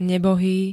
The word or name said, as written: nebohý